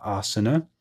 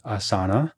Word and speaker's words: asana: I like to use the app Asana, which of course they say Asana, But we are yogis. We know that's not the Sanskrit pronunciation. It's asana. So that's what I call the app, asana.